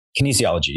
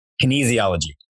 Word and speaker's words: kinesiology: Outside of rowing, when you were in the classroom, you majored in kinesthesiology. Is that right? – Kinesiology. Yeah. – How do you pronounce it? – Kinesiology.